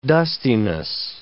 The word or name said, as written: dustiness